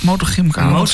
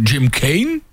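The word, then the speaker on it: Motorgymkhana: Motorgymkana, zeg ik het goed? Motorgymkana. – Kana. Ja, want sommigen zeggen Jim Kane.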